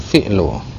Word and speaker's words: fi'lu: Wal fi'lu, ini kalimat fi'il itu kita bacanya huruf fa-nya di kasroh ya, mengecualikan dari fa'lu yang huruf fa-nya di fathah.